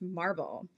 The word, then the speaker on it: MRBL: It's spelled M R B L, but pronounced Marble.